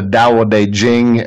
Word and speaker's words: Tao Te Ching: Probably my favorite book on planet Earth, the text that resonates with me the most, is what's called the Tao Te Ching, and it's pronounced that way, but it's spelled Taoteching, and the translation is by Stephen Mitchell.